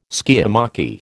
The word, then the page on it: skiamachy: American English